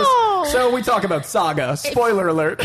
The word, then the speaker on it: Saga: doesn't rhyme with Sega. I thought it did. It doesn't. I tried to make it rhyme. – He's been name-dropping Saga, and he was trying to be coy, well, coy about it. – You call it... – I know. I was like, it rhymes with a certain Genesis system. No, that's Sega. No, that doesn't rhyme.